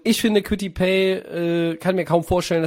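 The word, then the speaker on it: Kwity Paye: Äh, Quitty Pay, äh, der tatsächlich so ausgesprochen wird,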